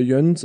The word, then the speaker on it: Jöns: Ich glaube, er wird dann auch eher Jöns ausgesprochen als mit einem langen Ö. Aber der hat das damals J-Ö-H-N-Z geschrieben.